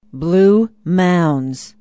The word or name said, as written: blue mounds